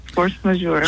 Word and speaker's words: Force Majeure: Force Majeure, did I pronounce that correctly? – Yes, Force Majeure.